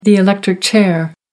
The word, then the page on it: electric chair: American English